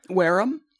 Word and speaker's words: Wareham: Wareham? – Wareham. – Wareham? – W-A-R-E-H-A-M.